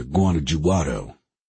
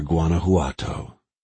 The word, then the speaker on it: Guanajuato: "Makes sense that they're heading to Guanajuato," pronouncing the J. Pope sighed. "There's no evidence Villa's anywhere near Guanajuato," he said, pronouncing the word correctly, replacing the J sound with an H sound.